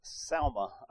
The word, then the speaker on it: Salma: Again, she married a man named Salma, I believe is how you pronounce it. S-A-L-M-A is the way the Hebrew has it written out.